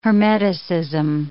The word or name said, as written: hermeticism